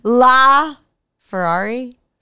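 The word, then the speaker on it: LaFerrari: How do I say that car? – You got it. – La Ferrari? Something like that. – Sounds right. – It's one word.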